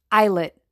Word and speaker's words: islet: Don't pronounce the S. It's islet cells of the pancreas that secrete insulin.